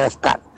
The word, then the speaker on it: Descartes: Descartes. Es un poeta francés. Ajá. Se escribe Descartes. Se pronuncia, sí, Descartes.